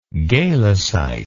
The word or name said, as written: gaylussite